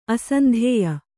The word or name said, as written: ಆಶಾಂತ್ಯ